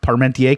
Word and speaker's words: Parmentier: Can you spell parmentier, please? – P-A-R-M-E-N-T-I-E-R. – French. Silent R.